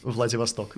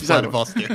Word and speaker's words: Vladivostok: Vladivostok. Vladivostok, if you say it like in the English way. – Yeah, poorly. – If you say it poorly, it's Vladivostok.